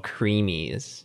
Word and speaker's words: cremees: It's spelled weird too. It's like CREMEES. It's called creamies.